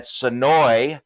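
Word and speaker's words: Senoia: Sonoy Raceway. I learned how to say Sonoy now. – Okay. – Finally, that's how the locals say it. The A is silent, Zach.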